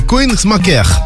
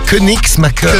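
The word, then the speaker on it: Koenigsmacker: Alors, Koenigsmacher. – Koenigsmacher. – Voilà, en Moselle, bref. – C'est Koenigsmacher.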